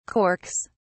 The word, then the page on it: corks: British English